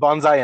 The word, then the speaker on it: Banzai: And then we had Senzi Ben, I can't ever pronounce their names. – Shenzi, Banzai, and Ed. – Yes, thank you.